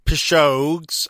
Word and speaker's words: Pishogues: The band are called the Pishogues. Yeah, Pishogues, and that's my understanding of how you say it.